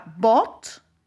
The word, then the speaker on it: bought: In the box, you have bought. That is an irregular verb. Okay? It comes from buy, comprar. by bought. Ok, and check the pronunciation. Fíjense cómo se pronuncia. Bought. By bought. Very good.